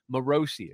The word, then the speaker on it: Morrissey: People were saying Morrissey earlier, and it got in my head. Morrissey is how you say it.